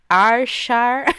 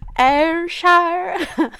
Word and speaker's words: Ayrshire: På gården Lochfield nära Darvel i Ayrshire. – Ayrshire. – I Skottland. I am not Scottish. I don't know how to pronounce these things. I just... guess.